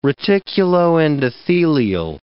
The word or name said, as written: reticuloendothelial